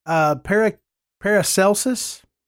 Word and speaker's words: Paracelsus: And so an alchemist, Paracelsus, did I say that right? – Yep.